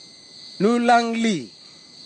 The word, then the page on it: nulagli: kwamera; Tanna